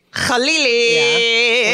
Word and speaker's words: Khalili: So yeah, Chemda, your last name is Khalil. – it's spelled Khalili, but it's actually Khalili. – Khalili.